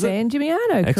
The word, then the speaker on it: San Gimignano: Now, and the other town that I'm going to struggle to pronounce, San Gimignano, is San Gimignano, correct.